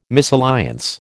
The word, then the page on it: misalliance: American English